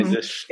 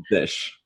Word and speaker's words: zsh: Using my ZSH? – Yeah. – My Zish. – Yeah. – So, Ben, I say ZSH. You say Z Shell, you are pronouncing Zish.